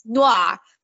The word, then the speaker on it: noir: That's not how you say it. It's noir. Noir. – Noir. – These are people who are supposed to be trained in French cuisine. They can fucking say the word right. They can say the fucking word right. – They should say the word wrong, but the voice I just may not be able to. – There's no you there. There's no fucking you in that word. It's not a two-syllable word.